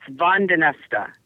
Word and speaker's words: Van den Neste: It's Vandenesta. It's actually Flemish.